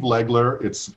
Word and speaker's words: Legler: I have a simple name, Steve Legler. It's short. It's easy to pronounce. There's only one vowel. It's an E. It shows up four times.